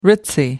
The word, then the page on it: ritzy: American English